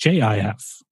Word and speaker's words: GIF: Creator says it is GIF is pronounced JIF, okay. And then it says he is wrong.